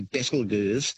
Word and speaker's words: Betelgeuse: It's a fun way of pronouncing the name, actually, Betelgeuse. But the correct pronunciation is said to be Betelgeuse, which comes from an old Arabic name, meaning the armpit of the giant.